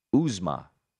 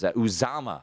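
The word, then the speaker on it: Uzomah: I do like CJ Uzma. I can never pronounce it. The tight end for the Bengals. I think Joe Burrow could be under duress. He has Uzama, I think is the proper pronunciation.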